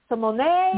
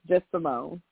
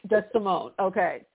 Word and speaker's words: Samoné: Everyone wants to say it because that's how it's spelled. But, no, it's pronounced Simone. It's just Simone. – Just Simone. Okay.